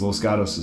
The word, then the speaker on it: Los Gatos: And I make the joke that you know you're not from Los Gatos when you call it Los Gatos. Because Los Gatos is spelled L-O-S-G-A-T-O-S, but we say Los Gatos.